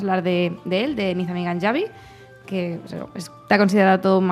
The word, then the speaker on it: Ganjavi: Bueno, está perfecto pronunciado, solo es Ganjavi, porque en persa y en árabe es la letra es como si fuera una Y nuestra.